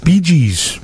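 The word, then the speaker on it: BJ's: It's BG's. – BG's Pizza, like the band, but with a J.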